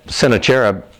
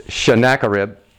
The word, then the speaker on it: Sennacherib: And Sennacherib, I used to pronounce that Sennacherib. I understand it's Sennacherib.